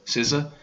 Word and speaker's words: SZA: SZA, is that how you say that, or is it like SZA? – SZA.